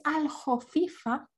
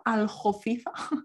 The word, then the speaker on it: aljofifa: la palabra en el diccionario es aljofifa con J, pero en Andalucía se dice con G, o se Sí. – pronuncia y se escribe con G. Pero la palabra realmente, mamá, es con J, aljofifa, del árabe.